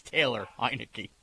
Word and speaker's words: Heinicke: Taylor Heinicke.